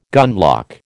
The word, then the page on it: gunlock: American English